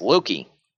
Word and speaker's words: Loki: is Loki. And even though it's low-key, which is spelled a little differently, mm-hmm.